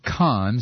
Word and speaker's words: con: However, C-O-N in French is pronounced with a long O, as in cone, and the N is not pronounced.